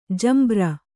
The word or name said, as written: ಜಂಬೂರಿ